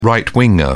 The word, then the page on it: right winger: British English